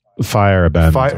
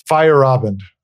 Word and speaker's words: Feyerabend: I said Feyerabend? – It's Feyerabend. – Feyerabend. Sorry about that. – Feyerabend. Yeah, Feyerabend. – Yeah. You got to remember that A in the middle of it.